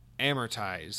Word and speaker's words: amortize: As a side note, it's Emeritus, which is gradually write off the initial cost. So the word is amortize. So I was definitely pronouncing that wrong.